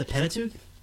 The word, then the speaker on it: Pentateuch: The Pentateuch? – That's it.